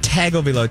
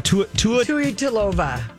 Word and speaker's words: Tagovailoa: Not Tagovailoa. Tuatalova.